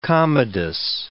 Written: Commodus

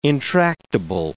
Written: intractable

